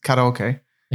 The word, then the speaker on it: karaoke: Yeah, because karaoke is, so it's actually pronounced karaoke. – Okay. – And it's Japanese. It's a Japanese word.